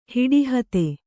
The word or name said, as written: ಹಿಡಿಹತೆ